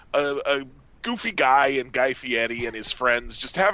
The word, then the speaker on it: Fieri: I would kind of go more towards like Fieri. – Mm-hmm. – Like, try and really. It's a rolled R, but he really hits it like D.